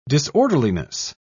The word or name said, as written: disorderliness